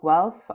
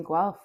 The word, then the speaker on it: Guelph: Well, most people like to spell it G-W-E-L-F, which I'm okay with, honestly. – But you actually spell it G-U-E-L-P-H, right? – That's correct, yeah.